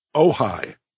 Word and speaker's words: Ojai: Is it Ojai? O-J-A-I? – Ojai. – Ojai. You know, that's right.